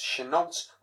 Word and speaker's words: Chenault: It's spelled Chenault like Renault. It's pronounced Chenault like no other word in the English language that I can think of.